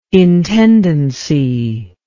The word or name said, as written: intendency